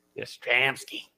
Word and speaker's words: Yastrzemski: I like Yastrzemski too. – Yastrzemski.